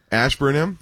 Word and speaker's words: Ashburnham: At Lock 20 Ambersham Ambersmish. Yeah, yeah. We don't know how to pronounce it. – Ashburnham. – That's the one.